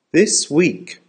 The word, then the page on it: this week: American English